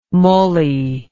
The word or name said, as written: mulley